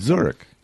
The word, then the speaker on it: Zurich: Is that how you pronounce Zurich. – it? Zurich.